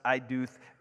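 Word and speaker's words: eidoth (עדות): but it's a Hebrew word, eidoth, right? A-Y-D-O-O-T-H is how we would spell it in the English, pronounced eidoth,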